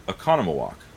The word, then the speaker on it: Oconomowoc: Oconomowoc. That's how it's pronounced.